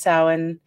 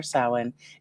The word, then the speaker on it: Samhain: And you just click under events and it's our Samhain. It's spelled Samhain. – Oh. – But it said Samhain.